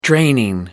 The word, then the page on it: draining: British English